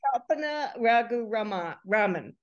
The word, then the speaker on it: Kalpana Raghuraman: And that is Kalpana Raghuraman.